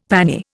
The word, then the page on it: pannier: Indian